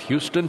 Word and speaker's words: Houston: Now, Houston Street in Manhattan, pronounced Houston, spelled like Houston. Nothing to do with Texas.